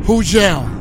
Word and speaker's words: Hujel: Hujel. And the G is silent.